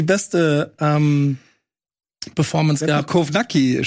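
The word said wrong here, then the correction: Kownacki: schon, äh, Kovnatschki, ah.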